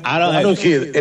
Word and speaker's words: Arrowhead: Arrowhead.